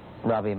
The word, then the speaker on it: Ravi: My name is Ravi Maharaj. Ravi is spelled R-A-V-I, and it's short for an Indian name, Rabindranath.